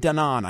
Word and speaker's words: Danon: Danon Danon, I think is how it's pronounced.